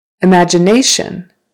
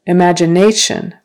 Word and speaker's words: imagination: Imagination. Imagination. Now it's on the next to the last syllable.